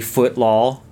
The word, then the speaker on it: Footlol: And no, I did not mispronounce that. It is actually Footlaw, F-O-O-T-L-O-L, with the L-O-L being capitalized.